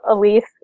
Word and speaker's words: Elise: Matt, and then R-U-Z-I-C-K-A. – This is Elise, and mine is my name, which is E-L-I-S-E, Worthy, like the word, W-O-R-T-H-Y.